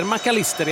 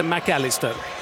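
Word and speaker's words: Mac Allister: De säger McAllister i Argentina, de säger inte McAllister, även om man har ett engelskklingande namn.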